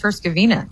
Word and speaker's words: Herzegovina: I think I'm impressed you can pronounce Herzegovina.